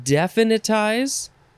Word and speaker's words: definitize: The first word in this episode is definitize. Or you can emphasize a couple different syllables. Definitize or definitize. Definitize. D-E-F-I-N-I-T-I-Z-E.